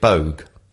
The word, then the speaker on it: Boag: Because everybody else that's called Boag pronounce it Boag. – Yeah. – So there you go. – But it's more of a... – Boag. It's like bouquet instead of bucket.